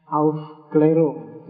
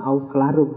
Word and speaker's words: Aufklärung: Bahasa Jerman bacanya Aufklärung, jadi enggak, bukan Aufklärung. Tapi kamu baca Aufklärung juga enggak apa-apa.